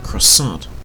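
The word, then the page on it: croissant: Canadian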